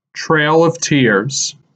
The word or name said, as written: Trail of Tears